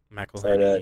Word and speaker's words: McElhenney: McElhenney.